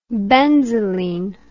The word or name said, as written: benzoline